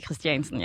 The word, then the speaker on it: Christiansen: Jamen tak fordi at du udtaler mit efternavn forkert. Gjorde jeg det? Ja. Christiansen? Det er Christiansen, ja. – Jeg sagde Christiansen. Nej, du sagde Christensen.